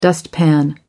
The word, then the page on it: dustpan: American English